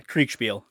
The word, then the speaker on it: Kriegsspiel: Yeah, Kriegsspiel.